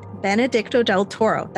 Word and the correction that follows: Benicio del Toro: It's Benicio del Toro. – Benicio del Toro.